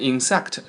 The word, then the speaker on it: insect: 好，那我们来看看他说这个部分哪部分呢 ?about insect, insect, I-N-S-E-C-T, 啊，重音在 IN 上面，不要念成 insect, 是 insect, insect, 昆虫虫子啊。